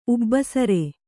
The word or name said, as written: ಉಬ್ಬಸರೆ